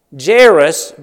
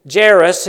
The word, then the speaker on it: Jair: Verse 22, "And behold, there cometh one of the rulers of the synagogue, Jairus by name." So the man with the request, his name is Jairus. His name would be equivalent to one of Israel's judges, Jair, or Jair, as it's sometimes pronounced.